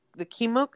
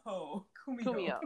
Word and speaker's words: kumiho: So there's a scene where she's reading the story about the Ninetales, about the Kimuku. Is that Kimuku? Is that how you say it? – I forgot how to say it. Kimuha. – The Kimuku. The Ninetale spirit. – Kumiho.